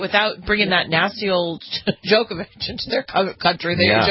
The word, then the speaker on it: Djokovic: Is that Djokovic. – How you pronounce it? – Mm-hmm. – The D is silent?